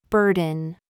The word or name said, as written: BOB